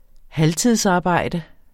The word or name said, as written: halvtidsarbejde